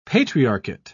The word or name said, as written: patriarchate